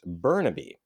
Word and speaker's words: Burnaby: It's pronounced Burnaby.